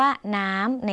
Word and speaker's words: น้ำ: คำว่าน้ำในภาษาลาวออกเสียงสั้นเ